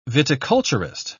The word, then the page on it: viticulturist: British English